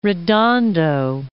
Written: Redondo Beach

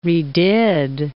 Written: redid

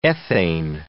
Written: ethane

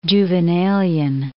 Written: Juvenalian

